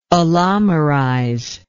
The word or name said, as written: allomerization